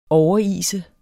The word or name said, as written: overise